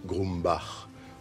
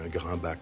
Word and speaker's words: Grumbach: Le mien est un nom difficile à prononcer, c'est un nom alsacien. Grumbach, ça devient Grimbach dans le meilleur des cas.